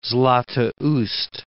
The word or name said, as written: Zlatoust